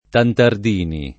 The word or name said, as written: Tantardini